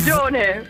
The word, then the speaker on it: Prigione: En je hebt het niet gehoord, maar ik heb jouw tweede naam uitgesproken als Prikicione. Klopt dat? – Bijna. Ja, maar is heel goed. Heel goed. Het is inderdaad altijd zo'n beetje zo'n toets hoe mensen dat uitspreken. Maar dat is heel goed. – Wat is het wel? Doe jij het eens even... – Prigione.